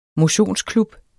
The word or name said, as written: motionsklub